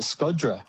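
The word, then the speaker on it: Shkodra: Yeah, and we went to Skoda, which they actually pronounce Scod. They pronounce it Skodra.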